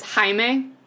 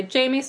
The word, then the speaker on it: Jaime: In like Spanish, I believe it's like, it's Jaime. And I think that's how you spell it. But some people spell it that way, but still say Jamie.